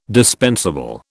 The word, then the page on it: dispensable: British English